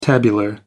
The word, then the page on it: tabular: American English